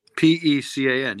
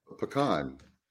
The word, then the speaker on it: pecan: Isn't it P E A C A N? Is that not how you spell it? P E C A N. Yeah, yeah. Pecan. Pecan. Pecan. There you go. Thank you. Pe- pecan. No, pecan. Pecan.